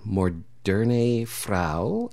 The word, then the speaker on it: Moderne Frau: So we just heard the title track of your record, Moderne Frau. – Yes. – Okay, I know I did not say that correctly. How do you say it correctly? – Moderne Frau. – Thank you. It's in German.